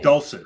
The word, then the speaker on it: Dulcich: Dulcich, Greg Dulcich.